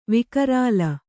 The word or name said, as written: ವಿಕರಾಲ